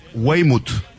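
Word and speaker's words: Waymo: Waino, no sé si se pronuncia así, ¿verdad? O Waino. – Me dice Luis María Morínigo, se pronuncia Weymouth.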